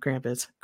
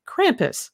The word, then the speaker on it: Krampus: Yeah, I just did the American pronunciation of Krampus. Krampus.